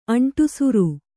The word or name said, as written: ಅಂಟುಸುರು